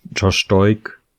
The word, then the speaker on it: Josh Doig: Ist glaube ich auch heute der Liga gemeldet worden, Josh Doig, ist das richtig ausgesprochen? – Ja.